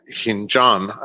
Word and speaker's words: Khinjan: And we arrived eventually at a small village called Hinjan. I'm probably mispronouncing it, but in English, it's transliterated as K-H-I-N-J-A-N.